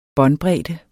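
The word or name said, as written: Båndbredde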